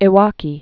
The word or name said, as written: Iwaki